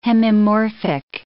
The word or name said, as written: hemimorphic